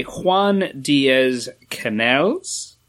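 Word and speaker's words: Díaz Canales: So this is by Juan Diaz Canales and Juanjo Grandio? I believe that's how their names are said. – Yeah, that's a pronunciation.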